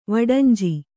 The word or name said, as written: ವಡಂಜಿ